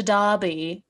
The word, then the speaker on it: Derby: women's national team players abroad, there was the Manchester Derby this past weekend, which is spelled like derby, but it's pronounced like derby.